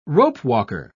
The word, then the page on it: ropewalker: British English